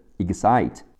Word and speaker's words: excite: 动词使激动使兴奋 excite e x c i t e excite e x 读 x c i 读 s i si x 的 s 和 si 的 s 两个连在一起 excite，最后加上 t e 结尾不发音。